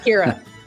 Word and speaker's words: Ciara: So yes, Ciara, like Knightley.